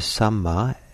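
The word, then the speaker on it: sammā: And the Pali word is samma, S-A-M-M, long A.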